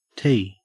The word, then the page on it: tea: Australian English